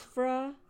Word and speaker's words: Shiphrah: It says the king of Egypt spoke to the Hebrew midwives, of whom the name of one was you'll have to pronounce that name Shepharah. – Shiphrah. Yeah, huh?